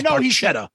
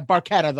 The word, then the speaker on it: Barchetta: So Red Barchetta. Am I saying that correctly? – Well, Getty says Barchetta. – Yeah, Barchetta, those.